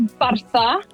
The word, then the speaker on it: Bartha: Oh, I don't think you'd be able to say it, but it's Bartha. It's a Welsh name. – Bartha, how do you spell that? – B-A-R-T-H-A.